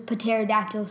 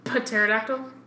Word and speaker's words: pterodactyl: I love to go on hikes, love bike riding, and then love how August uses his pterodactyl sounds. – Pterodactyl? – Pterodactyl. – We're pronouncing the P in front of pterodactyl now.